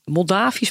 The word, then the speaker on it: Moldavisch: Maar eh, het lijkt wel of hij gesponsord wordt door het Moldavisch verkeersbureau. Zeg ik dat goed? Moldo Moldo Moldavië? Ja, Moldavisch.